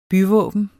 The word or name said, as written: byvåben